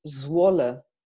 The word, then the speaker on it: Zwolle: I'm in a city called Zwolle. It's very difficult to pronounce, but it's the capital city of the province Overijssel. So that's wow. Where I am. – So Zwolle. – Zwolle.